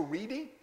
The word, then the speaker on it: Redi: There's a man by the name of Francisco Reedy. Some people pronounce his name Reddy, R-E-D-I.